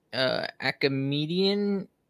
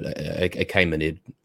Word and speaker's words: Achaemenid: You talked a little bit about how they, or you were talking about the Achaemenidian dynasty. Is that what you? – It can be pronounced different ways, but Achaemenid doesn't. Yeah, it's all the same.